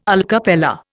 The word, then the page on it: alla cappella: Indian